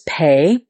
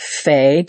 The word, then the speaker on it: Pe: The letter is Pe. It also can be pronounced Fe, depending on the little dots it's given. It has a dot inside of it. It's P. And if it doesn't have a dot, it's a like an F sound.